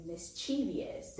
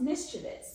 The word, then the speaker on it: mischievous: Just recently, I was watching a YouTube video and I found out that all this time I've been pronouncing mischievous incorrectly by saying mischievous, and it's actually pronounced mischievous. So now I'm trying to train myself to always say mischievous.